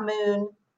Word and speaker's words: Moon: Um, but I'm Tonja dot moon